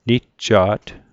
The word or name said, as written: nijja't